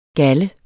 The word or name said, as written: galde